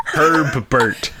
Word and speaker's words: Herbert: You do not have to pronounce both B's. – What? How do you guys not know this? Please just say it right. Herbert.